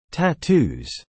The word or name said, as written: Tattoos